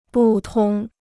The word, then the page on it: 不通: bù tōng